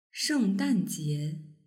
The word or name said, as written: shengdanjie